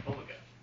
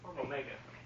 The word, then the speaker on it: Omega: If you're speaking Greek, that you would say Omega. But in English, we say Omega. – Okay. – We just add the emphasis on the different syllables.